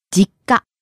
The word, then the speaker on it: 実家: 実家. – Starting low and rising on the last syllable. か.